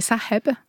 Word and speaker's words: Saheb: Avant qu'on commence cette émission, vous m'avez appris à bien prononcer votre nom. Parce que moi, je prononce Yamina Saheb. – Oui. – Mais en réalité, ce n'est pas comme ça qu'il faudra le prononcer. – Non, c'est Saheb.